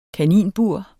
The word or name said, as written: kaninbur